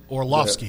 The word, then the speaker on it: Orlovsky: Dan, is it Orlovsky? Is that how you pronounce it? – Orlovsky, yeah. – Orlovsky? – Yep.